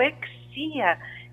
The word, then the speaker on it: Exea: Vamos acessar agora o Museu Exia. Exia se escreve E-X-E-A.